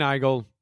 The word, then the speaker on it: Heigl: Puis celle-là, c'est vraiment, tu, encore une fois, c'est similitudes physiques euh, physique par Catherine Hale. C'est la fille qui joue dans 27 robes puis Knocked Up. – Ah, Catherine Heigl. – Heigl, c'est comme ça qu'on le prononce.